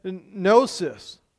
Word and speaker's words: gnosis: Technically, this is a Greek word called gnosis. The G is silent.